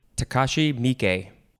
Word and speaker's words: Takashi Miike: Takashi Miike.